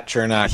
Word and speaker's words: Cernak: Uh, uh, Erik Cernak. Is am saying that right? Yeah, Cernak. He is a guy, guy.